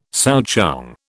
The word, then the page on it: souchong: British English